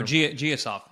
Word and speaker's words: Gassiev: Gassiev. I probably butchered his name. That's why the Pacquiao fight with Rolly kind of fell through. Like, this guy's Simon Matcher. – No, Giasov. Yeah, Giasov.